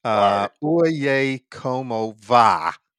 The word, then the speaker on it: Oye Como Va: Oye Como Va.